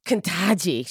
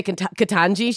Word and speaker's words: Ketanji: And I imagine at some point she might just be like, Kentaji. She's going to say her name wrong. She's not going to say Ketanji. She's going to say Kentaji.